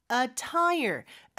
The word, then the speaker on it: attire: 보통 우리말로는 그렇게 얘기를 안 하니까 이렇게 권장합니다라고 했는데 business casual attire. attire가 복장이라는 뜻입니다. 그래서 우리 자동차에 다는 타이어 있잖아요. 그거 하나랑 발음은 똑같아요.